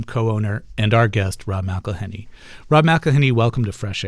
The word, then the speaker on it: McElhenney: It's McElhenney. – McElhenney. – McElhenney. – McElhenney. – If there was doubt, now there won't be any. It's McElhenney.